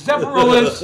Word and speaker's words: Zephyrhills: It's Texar. It's Zephyrhills. – Sounds like you're from Chicago or something. – Where we pronounce things. – Except for the S. – With letters. – Except for the S. – Right. – That's silent.